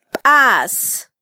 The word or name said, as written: bas